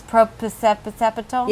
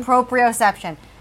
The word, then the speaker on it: proprioception: Proprioceptal? – Yes. – Proprioceptal? – Proprioception? – Proprioception.